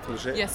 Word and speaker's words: Mižerija: I pronounced it right? – Mižerija. – Mižerija. – Yeah.